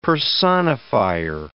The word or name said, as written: personifier